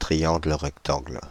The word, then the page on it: triangle rectangle: [tʁi.jɑ̃ɡl ʁək.tɑ̃ɡl]; /tʁi.jɑ̃ɡl ʁɛk.tɑ̃ɡl/